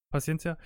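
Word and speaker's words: Paciência: sagt, okay, komm, Rebic, jetzt bringen wir mal Jovic und dann hat er ähm, Pat Pacienka, Pacienka, wie würde, er, Paciencia, wie würde er ausgesprochen? – Paciencia.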